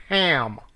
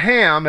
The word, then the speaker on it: Cham: They always spell it H-A-M, and people say Ham. It's not Ham, it's Ham, with the German Ach, C-H-A-M. That's proper spelling of his name, and pronunciation is Ham. It's a guttural kh.